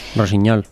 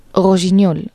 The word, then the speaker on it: Rossignol: Rossignol. – Rossignol, sí. – Pero ahora niña. – No tiene ñ. – No es GN, sí, pero GN es ñ.